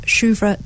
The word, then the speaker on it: Shuvra: So just go and look up Shuvra Deb. I'll spell that S-H-U-V-R-A. My surname Deb, D-E-B, and With You.